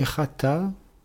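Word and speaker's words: chata (חטא): Le mot pour péché est chata, un ret, un tet et un aleph. Rete signifie le péché, le crime. Prononcer rite, c'est la purification. Prononcer chata, ça veut dire manquer, échouer. Ce sont les mêmes lettres, vocalisées différemment, qui donne le sens de échouer, de manquer et aussi de pécher.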